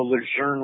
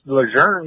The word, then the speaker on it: Lejeune: Well, if he's, once it gets pronounced Lejeune, yeah, he's a frog. Lejeune. That's the American way.